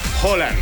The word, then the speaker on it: Haaland: se pronuncia Haaland o Holland o Haaland, ¿no? O Haaland o... Bueno, Alfredo González, comentarista de Fórmula Deportes, ¿cómo se pronuncia? – Holland. – Holland. – Holland. – Es con O.